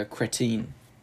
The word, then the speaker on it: cretin: A C-R-E-T-I-N. – First. – It's, it means a stupid person. – It's cretin, bro. – Oh. – Again, with the cretin. Pronunciation here. – So, hey, yo, no, let's not move past this. Your pronunciation, man, it's killing me. – Cretin. – Yeah, there we go.